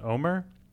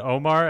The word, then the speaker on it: Omer: that I guess his name's pronounced Omer, because I feel like they said Omar at the beginning, and now they've all clearly been saying Omer in a way that is different than the opening one.